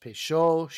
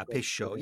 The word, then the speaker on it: Peychaud: I assumed it was pronounced Peychaud. Show means hot. – Yeah, Peychaud. Yeah, that's the way you pronounce it.